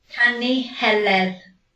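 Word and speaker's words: Canu Heledd: And it is called the Song of Helen, or the Canu Heleth, I think it's pronounced in Welsh. And it's a story. How do you say it? – Canu Heleth. – There you go, that.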